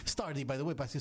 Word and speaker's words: Stardy: C'est Stardy. – Stardy. – Alors, c'est S-T-A-R-D-Y, mais prononcé Stardy.